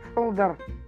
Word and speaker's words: Солдар: Солдар. – Да. Вот, и ударение на второй слог.